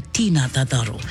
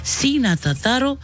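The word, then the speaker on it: Ti na Tataro: So you are going to say this, C na Tataro, it's spelled T-I. You can't say Tina Tataro. Sina Tataro, Tamara Are Ikarawa.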